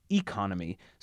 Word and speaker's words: economy: economy is sometimes pronounced economy, so this one is an exception to the rule.